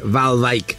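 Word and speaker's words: Waalwijk: Exactamente, RKC Valvik. ah, em, Valvik. Bueno, acá, así lo pronuncian acá.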